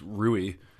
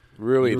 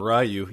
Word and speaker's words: Rui: I got closer to the correct pronunciation on this. It's Rui. – Rui, love it. – Ryu, you went full.